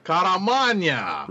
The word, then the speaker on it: Caramagna: and letters by GBC's Joe Kamaramanga. So, you may or may not have seen the trailer for this. – How can you butcher the name of our favorite paisan? Caramagna.